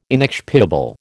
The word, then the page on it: inexpiable: American English